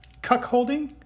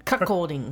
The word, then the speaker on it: cuckolding: So you were just explaining to me that it is actually not, it's pronounced cuckolding? – Cuckolding. – Cuckolding. – Cuckolding. – Cuckolding. – There's no H. There is an H in the spelling, but I was told there's no H in the pronunciation. So it's cuckolding.